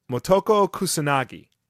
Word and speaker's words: Motoko Kusanagi: Major Motoko Kusanagi.